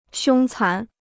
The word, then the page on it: 凶残: xiōng cán